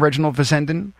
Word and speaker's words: Reginald Fessenden: Is that how you pronounce his name, Reginald Fessenden? – That's right, yes.